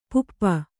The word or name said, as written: ಪುಪ್ಪ